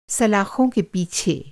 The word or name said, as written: सलाख़